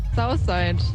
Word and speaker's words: Southside: Wie spricht man dieses Festival nochmal aus? – Southside. – Was sagst du? – Southside. – Southside.